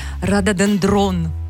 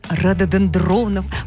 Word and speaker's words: рододендрон: Когда была на севере Таиланда, поднималась на самую высокую точку, и там оказался целый лес из рододендронов высотой, наверное, 5 метров. – Сейчас тебе напишут, что все-таки правильное ударение рододендрон.